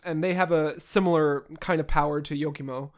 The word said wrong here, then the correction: Yomiko: I can never pronounce her Yomiko? – Name. Yomiko, sorry.